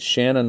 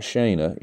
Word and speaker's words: Shanna: But Shanna, and by the way, it's Shanna, not Shana. You know, I talked to her about this because I always get it confused, and she said, well, just remember, Shanna rhymes with banana.